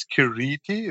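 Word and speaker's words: Keerti: It's Kirti. It's a double E, which is a long E.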